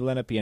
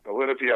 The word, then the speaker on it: Lenape: Okay, the Lenape, I've heard pronounced Lenape as well, is we are considered to be the grandfather nation of this particular area.